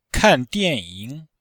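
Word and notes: kan dianying: kan4dian4ying3

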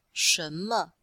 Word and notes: shen2me5: female

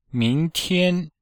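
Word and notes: mingtian: ming2tian1

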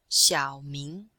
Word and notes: xiao3ming2: female

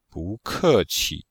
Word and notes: bu2ke4qi5: pinyin

